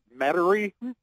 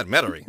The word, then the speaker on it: Metairie: So it's been a long time, and I guess I need to learn how to pronounce Metairie. – You just did it. You just said it correctly. Good job. – It? – Yeah, Metairie.